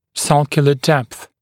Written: sulcular depth

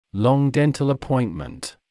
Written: long dental appointment

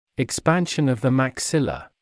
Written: expansion of the maxilla

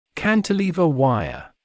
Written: cantilever wire

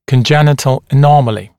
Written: congenital anomaly

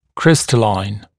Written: crystalline

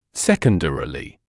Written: secondarily

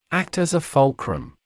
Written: act as a fulcrum

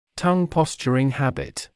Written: tongue posturing habit

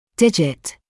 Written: digit

